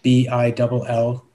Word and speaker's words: Billimoria: Nash Billimoria, Billimoria.